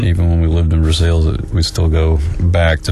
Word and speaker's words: Versailles: And due to my father's job, during childhood, we had to move to Central Kentucky and settled in a little town called Versailles, as the locals say.